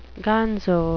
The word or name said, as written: gonzo